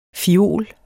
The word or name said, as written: FIOLER